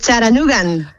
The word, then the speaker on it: Chattanooga: Es Charanugan. – Ah, Charanugan, vale, vale, vale. ¿Y el nombre de la ciudad cómo se pronuncia bien, bien? – Se dice Charanuga, Charanuga, con la T como muy suave, sí.